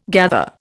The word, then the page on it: gather: Indian